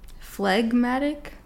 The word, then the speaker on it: phlegmatic: Phlegmatic? – Yeah.